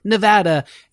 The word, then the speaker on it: Nevada: Don't you dare say Nevada in front. It's Nevada, for sure. – Okay. – And the best way to remember it is Nevatitude.